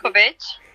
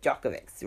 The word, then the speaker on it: Djokovic: How do you say the last Djokovic? – Novak Djokovic, right.